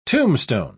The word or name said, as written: tombstone